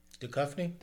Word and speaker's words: Duchovny: Duchovny. – Duchovny.